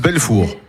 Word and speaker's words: Belfour: Si je me trompe pas, mais en plus vos micros fonctionnent, le duo Belfour. Est-ce que je prononce bien Belfour ou ça se prononce autrement? – Ça se prononce comme ça. – Ça se prononce Belfour.